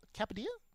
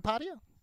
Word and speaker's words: Kapadia: Sheil Kapadia? – Kapadia. – I knew I was going to butcher that.